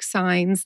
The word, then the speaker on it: Saenz: Her name is Kimberly Clark Sines, and that's how she pronounced it.